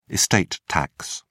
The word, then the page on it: estate tax: British English